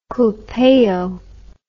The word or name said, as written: culpeo